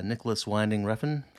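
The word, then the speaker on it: Nicolas Winding Refn: Nicholas Winding Refn, I think I pronounced that right. – You did.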